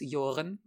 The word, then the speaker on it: Jooren: As for where you can find me, my full name is Alice Joren. My surname is spelled J-O-O-R-E-N, and you can find me on at Alice Joren on Twitter.